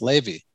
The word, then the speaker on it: Lavie: For some reason, my parents decided to spell my name L-A-V-I-E. So like the restaurant, the JFK airport, L-A-V-I-E. So it's like Lavi. You know, people pronounce it Lavi, but actually it is pronounced Levy.